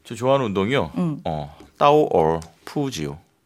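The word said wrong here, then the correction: 까오어 푸지요 (高尔夫球): 까오어 푸지요. – 아, 네. 자, 여러분 여기서 퀴즈 하나 나갈게요. 네. 지금 비슷하게 했어요. 되게 네. 제가 정확한 발음 해드릴게요. 까오어 푸지요. 까오어 푸지요.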